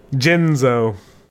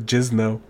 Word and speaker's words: Jinzo: We got Young Slifer and Big Jinzo. I gotta make sure I say it right instead of Jizno.